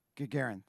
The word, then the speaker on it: Gagarin: On April 12th in 1961, a Soviet cosmonaut by the name of Yuri Gagarin, I'm sure I'm saying that wrong. Do you know Gagarin? Gagarin, thank you.